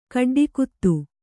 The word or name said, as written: ಕಡ್ಡಿಕುತ್ತು